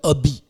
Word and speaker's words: Obi: Und die können ja das H nicht aussprechen. Ja. Ne? Die sagen ja statt... – Stumm. – Statt Hobby sagen die ja Obi.